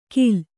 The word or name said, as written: ಖಿಲ